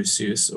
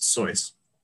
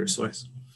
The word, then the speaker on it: Seuss: So, and speaking of pride and hubris, this week's story is The Zacks, written by Dr. Seuss, or as I was once taught, it was intended to be pronounced Soyce, to rhyme with choice, Dr. Soyce.